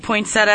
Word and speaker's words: poinsettia: And so since you pronounce all the letters, it is poinsettia. – Says you. Wait. – We have tweets and Facebooks about poinsettia and pronunciation from producer Gregory Alexander of the Hal Spark Show.